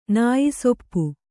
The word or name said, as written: ನಾಯಿಸೊಪ್ಪು